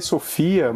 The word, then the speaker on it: SFIA: É, se, se lê Sofia, mas se escreve S-F-I-A, não tem o O.